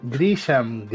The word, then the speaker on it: Grisham: Eh, es una película basada en los libros de John Grisman. No, Grisan. Grisan, Grisham, Grisham. perdón. John Grisham. Creo que sería la pronunciación lo más correcta posible que es un escritor eh,